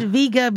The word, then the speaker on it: Vega: Dean is right if we live 3,000 years ago. – Oh, snap. He does have like a very old iPhone. – That's the classical pronunciation. – Okay. – The old pronunciation. – He's happy with that. He's making motions like, this is great. – Yeah. Most people today say Vega, okay, rather than Vega.